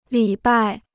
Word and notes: 礼拜: lǐ bài

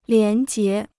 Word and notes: 廉洁: lián jié

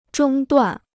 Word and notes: 中断: zhōng duàn

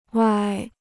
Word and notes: 歪: wāi

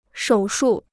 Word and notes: 手术: shǒu shù

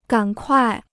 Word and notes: 赶快: gǎn kuài

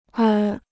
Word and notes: 而: ér

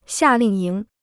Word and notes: 夏令营: xià lìng yíng

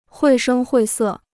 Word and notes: 绘声绘色: huì shēng huì sè

